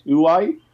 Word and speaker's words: Huawei: Huawei? – That's how I say it, yeah.